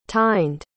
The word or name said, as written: tyned